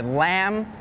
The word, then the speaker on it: lamb: And some examples with a silent B are lamb, thumb, and doubt.